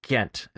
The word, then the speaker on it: Ghent: Ghent. Is that also the correct pronunciation? G-H-E-N-T. Excellent.